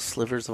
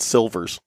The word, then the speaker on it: sliver: She pronounced slivers wrong and called them silvers, which silver. We always thought was funny. – It's a sliver. – The almond silvers. – The L is before the I.